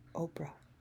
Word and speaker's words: Oprah: Oprah. Could you spell that, please? He said. I did. O-P-R-A-H. And the H is silent, I explained.